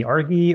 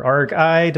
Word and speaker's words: ARGI: And there's this Rust library that I came across this week called maybe Argi or Argi, depending on how you want to pronounce it. It's ARGI.